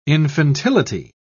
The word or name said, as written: infantility